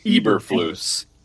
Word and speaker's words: Eberflus: Eberflus.